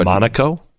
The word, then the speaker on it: Monaco: I've been to Monaco, which is right there. – But Monaco? – It's Monaco. That's how I actually pronounce it.